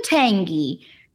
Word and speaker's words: Olentangy: Is that how you say it? Olentangy? – Yep. – Olentangy? – Yep, that's it. – Olentangy.